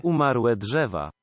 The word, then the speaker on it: Umarłe drzewa: So either Umarte or Umarle Drzewa. I don't know if that is accurate. – Umarłe drzewa. – There you go. According to Google Translate, that is the pronunciation.